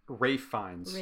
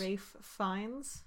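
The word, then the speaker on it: Ralph Fiennes: How you pronounce his name? – I believe it's Fiennes. – Rafe Fiennes. – Rafe Fiennes. – Yeah. – Rafe Fiennes. Okay.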